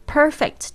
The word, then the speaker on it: perfect: Perfect 就是完美的，P E R F E C T，perfect。那个后面那个 C T 有很多同学不知道怎么念，你不要就是爆破出来发克特的声音，就会感觉口音非常的重。